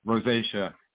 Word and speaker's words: rosacea: Rosacea.